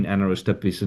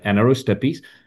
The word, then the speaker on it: Anaerostipes: So if you go on our website and you put in Anaerostepis and it's spelled Anaerostipes, it's kind of a long word, but Anaerostepis.